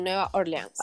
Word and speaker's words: Nueva Orleans: Sí, pero eh, mira, es que encontré uno que me llamó mucho la atención en, Nueva Orleans, lo dije bien. Mm. – Sí, Nueva Orleans.